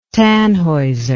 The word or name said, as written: Tannhauser